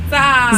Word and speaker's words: SZA: Before that was Drew Barrymore by SZA. Is that how you say it? – Zah. – Zah. – I think it's SZA.